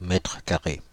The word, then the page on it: mètre carré: Paris: IPA: [mɛtʁ ka.ʁe]; France (Île-de-France): IPA: /mɛtʁ ka.ʁe/; IPA: /mɛ.trə.ka.ʁe/